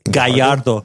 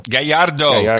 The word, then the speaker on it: Gajardo: Am I saying that right? G-A-J-A-R-D-O. – Gallardo. – Gallardo.